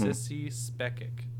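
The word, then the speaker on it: Sissy Spacek: Sissy Spekic, that's how you say it.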